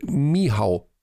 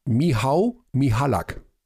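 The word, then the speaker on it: Michał: Michal Michalak. – Nein. Alexander Supertramp sagt, der Vorname wird Mihau ausgesprochen. Mihau Michalak.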